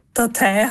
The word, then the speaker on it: Data: Ich habe gelernt, dass Data amerikanisch ausgesprochen Datei heißt, einfach, ja, what the fuck.